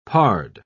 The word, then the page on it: pard: British English